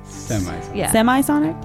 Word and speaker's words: Semisonic: It's by Semisonic. Is that how you say it? – Semisonic.